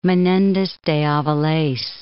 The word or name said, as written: Menendez de Aviles